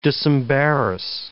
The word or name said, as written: disembarrass